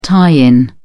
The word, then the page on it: tie-in: British English